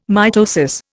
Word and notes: mitosis: Indian

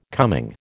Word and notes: coming: American English

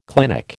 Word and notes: clinic: American English

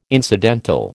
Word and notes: incidental: American English

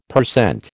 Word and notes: per cent: American English

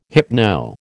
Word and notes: hypno: American English

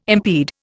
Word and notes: impede: Indian

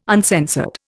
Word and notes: uncensored: Indian

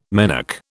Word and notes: menarche: British English

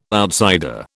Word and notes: outsider: British English

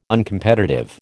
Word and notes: Uncompetitive: American English

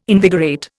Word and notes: invigorate: Indian

